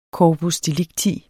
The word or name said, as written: corpus